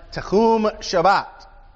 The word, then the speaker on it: Tehum Shabbat: A Sabbath's day's journey is a technical term in Hebrew. It's pronounced Tehum Shabbat.